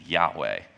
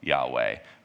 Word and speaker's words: Yahweh: And so the one that we're probably the most familiar with is Yahweh, which is often spelled without an A and an E. So it's Y-A-H-W-E-H.